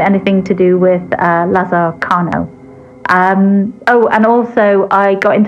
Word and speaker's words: Carnot: And they used the name, or he used the name, Lazare Carnot, or Carnot, but I don't think the T is pronounced in French. So Lazare Carnot.